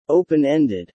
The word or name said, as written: open-ended